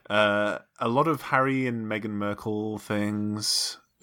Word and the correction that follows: Markle: Wait, how did you say her last name? – Meghan Merkel. How do you say it? – Markle. – Did I do it? – Markle. – How do you say it? Is it Meghan Markle? – It's Markle. Markle. – Oh. – Not Urkel with an M.